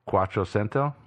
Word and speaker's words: Quattrocento: Quattrocento.